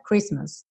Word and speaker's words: Christmas: Christmas, vê que o T tá pintado de cinza, e isso é uma característica da palavra Christmas, tá bom? Não é comum um T no meio da palavra não ser pronunciado, mas isso acontece aqui nessa palavra.